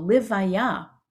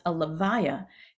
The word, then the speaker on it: levaya: In fact, the Hebrew word for funeral, levaya, sometimes pronounced a levaya, comes from the Hebrew word which means to accompany.